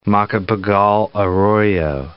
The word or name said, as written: Macapagal-Arroyo